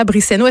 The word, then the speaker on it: Briceño: Bonjour Marc-Claude Ducas, bonjour Catalina Brisseno. Est-ce que je le prononce bien ton nom? Oui, c'est ah la petite vague sur le N.